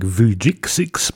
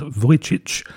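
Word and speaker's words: Vujicsics: If you were to pronounce it phonetically, you would say something like Vujicic, but apparently no, it's Vujicic, that performs traditional South Slavic, as in Serbian and Croatian folk music.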